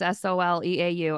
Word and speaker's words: Soleau: I'm the only Meredith Soleau on Facebook. My last name though is spelled weird. It's S O L E A U.